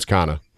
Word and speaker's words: Connor: So your name out east is Kana? – Kana, yep. Yep. That's how it's Kana.